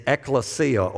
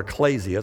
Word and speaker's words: ekklesia: The word church in the New Testament is ekklesia or ecclesia. It's according to how you pronounce it.